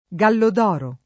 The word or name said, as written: Gallodoro